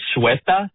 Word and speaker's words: Ceuta: It's the town of, and I believe I have the pronunciation here, Ceuta, C-U-E-T-A.